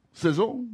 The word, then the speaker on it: saison: Saison? Am I saying that right? – Saison.